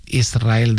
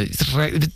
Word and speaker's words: Israel: Y de diminutivo, de chiquitito, Israel, que ella quería, porque Israel creo que le sonaba muy grande, ¿no? Israel, ¿no? – Y la S con la R es difícil de pronunciar.